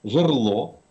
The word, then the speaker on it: жерло: написано, что надо говорить апостроф, вероисповедание, жерло, зубчатый, инженерия, кровоточить, кухонный, ободрить, сливовый, ходатайство, исчерпать, исчевель.